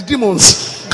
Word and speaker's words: demons: It's not diamonds, it's demons. – No es diamond, es demon.